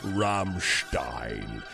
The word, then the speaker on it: Rammstein: Going into the 5 o'clock hour with style, we got Du Hast by the one and only, you got to say it like this, Rammstein.